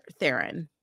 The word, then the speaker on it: Theron: No, she pronounced her name recently and like we all like make it sound fancier than it is. Like I think that the way you pronounce her name is like Charlize Theron. – Theron. Okay. – Theron. But I kind of think like Charlize, like make your last name sound like Theron.